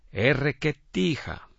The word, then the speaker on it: erreketija: Vamos al río en malecu se dice erreketija. Pero mejor dejemos que una persona de allí mismo, lo pronuncie como se debe.